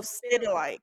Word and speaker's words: Stedelijk: Oh, Stedelijk.